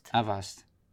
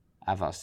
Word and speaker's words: Avast: Invest, hoe spreek je dat uit op zijn Tsjechisch? – Avast. – Avast, dat is een Tsjechisch.